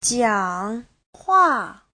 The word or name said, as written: jiang3hua4